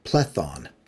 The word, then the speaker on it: Plethon: Another person who actually stays for a little bit is the Platonist that I've talked about before, George Gemestus Plethon, Plethon being the late Byzantine pronunciation of Plato.